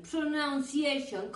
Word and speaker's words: pronunciation: Hay algunos angloparlantes incluso que dicen pronunciation. Pronunciation. Como si ahí estuviera escrito noun. Nombre, sustantivo, noun. Y no es pronunciation, es pronunciation. El mismo sonido de some y de money y de thumb que acabamos de ver.